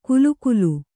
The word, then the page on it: ಕುಲುಕುಲು: kulukulu